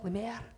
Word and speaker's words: Lemaire: So, yes, I've had Lemur, Lemaire. Um, for some reason, the hospital at St John's love a Lemaire. So, um, makes me very French. I'm Dion Lemaire.